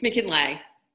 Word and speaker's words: Mackinlay: Mackinlay or Mackinlay? – It's Mackinlay.